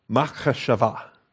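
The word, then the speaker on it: Machashava (מחשבה): Machashava. Everybody want to say that together? Machashava. Okay. That's not too bad. Machashava. All right. Put the accent on the final va.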